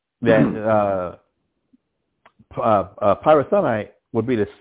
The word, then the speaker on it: Pirathonite: the Pirathonite, if I'm if I'm saying that right. – Tron. Pirathonite, yes, yes sir.